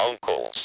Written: uncles

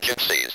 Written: gypsies

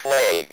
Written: plague

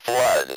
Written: flood

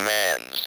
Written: man's

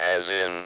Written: as in

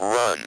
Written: run

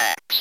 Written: ax

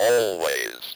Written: always

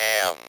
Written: am

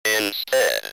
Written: instead

